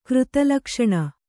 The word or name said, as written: ಕೃತಲಕ್ಷಣ